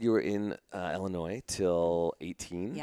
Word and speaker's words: Illinois: How do you say it? Illinois. I haven't heard, like with an E, almost like with an E at the front. – Like it's E-L-L-A-N-O-Y.